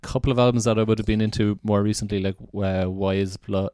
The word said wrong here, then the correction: Wise Blood: Wise Blood.